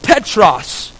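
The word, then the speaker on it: Petros (Πέτρος): If you look up Peter's name in a Greek dictionary, it is pronounced Petros, which means a small rock or stone.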